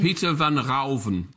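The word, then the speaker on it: Pieter van Ruijven: And in your book, you write, a turning point in Vermeer's life was on 30 of November, 1657, when he owned, is it Pietra Ruven? Am I saying it right? Peter Ruven. – Peter van Rauven.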